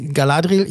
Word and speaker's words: Galadriel: Galadriel, hier wird so ausgesprochen, Galadriel.